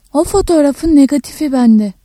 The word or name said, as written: fotoğraf